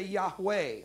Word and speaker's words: Yahweh: If you say Jehovah, it's incorrect. That's an English way to say a word. There's no such letters in Hebrew. You could never say Jehovah and be saying a Hebrew word. To pronounce it in Hebrew, you would say Yahweh, all right?